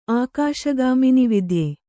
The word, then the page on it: ಆಕಾಶಗಾಮಿನಿವಿದ್ಯೆ: kannada